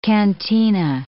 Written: cantina

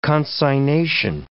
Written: consignation

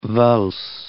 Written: valse

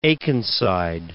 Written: Akenside

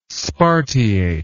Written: spartiate

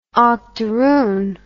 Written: octaroon

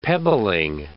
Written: pebbling

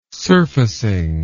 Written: surfacing

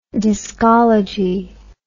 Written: discology

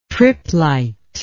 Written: triplite

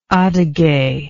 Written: Adighe

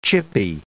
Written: chippy

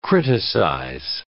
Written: criticize

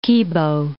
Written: Kibo

